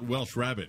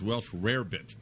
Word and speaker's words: rarebit: Because it's not Welsh rabbit. It's Welsh rarebit. People say that wrong all the time.